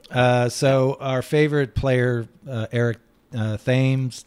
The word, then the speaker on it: Thames: So, Eric, it's official now on Foul Territory. Your last name is pronounced Thames.